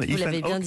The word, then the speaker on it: Ethan Hawke: Vous Ethan Hawke, c'est comme ça qu'on dit.